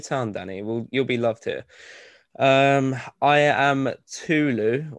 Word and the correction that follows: Tolu: You butchered that name, by the way. It's Tolu.